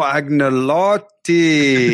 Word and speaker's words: Agnolotti: Agnolotti. – Oh, Agnolotti. – Yeah. Silent G.